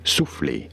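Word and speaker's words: soufflé: For reference, in French, it is said as souffle. Souffle. – Souffle. – In English, however, souffle is how it is said in English.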